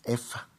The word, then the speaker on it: Hefe: Hay otras que son la EFA, que se escribe H-E-F-E.